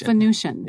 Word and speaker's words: Venusian: Venusian.